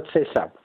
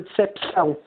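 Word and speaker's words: deceção: Dou-lhe um exemplo, por exemplo, eh, passa a redundância, eh, nós os portugueses temos a palavra, ou na língua portuguesa, a palavra de cessão. nós, na nova ortografia, eh, cortamos-lhe o P porque o P é mudo. Mas os brasileiros continuam a dizer decepção.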